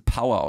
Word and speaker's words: Pauer: Und P-A-U-E-R? – Wie Bauer, aber mit hartem P, aber wieder trotzdem Power ausgesprochen. – Genau, dann. – P-A-U-E-R.